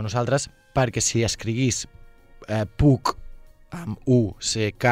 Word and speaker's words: Puck: Puc, escrit P-U-C-K, es pronunciaria semblant a PAC, això uh -huh.